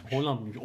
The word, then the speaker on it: Haaland: Holland Holland O üzerindeki noktalar Holland mı? Holland okunuyormuş.